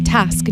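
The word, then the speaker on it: Tysk: It's spelled T-Y-S-K, T-Y-S-K, Task.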